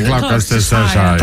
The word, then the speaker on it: Gloucestershire: Nie, nie, chwileczkę, bo ta audycja jest poprawna w wymowie tylko ja jedyna w naszym gronie potrafi. – Nie, Gloucestershire.